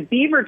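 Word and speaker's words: Biewer: And it was and it's spelled differently, but it's pronounced Beaver Terrier.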